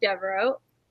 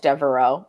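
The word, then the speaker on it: Deveraux: So my mom picked a book by Jude Devereaux. That's how you say her name, right? – Mm-hmm. I know that because one of the Golden Girls is Blanche Devereaux, and that's how I know how to pronounce her name.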